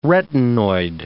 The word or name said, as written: retinoid